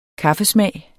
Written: kaffesmag

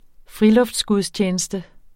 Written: friluftsgudstjeneste

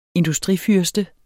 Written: industrifyrste

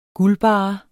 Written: guldbarre

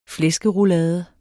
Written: flæskeroulade

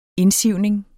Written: indsivning

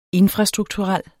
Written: infrastrukturel

